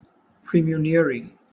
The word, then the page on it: praemunire: British English